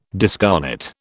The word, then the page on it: discarnate: British English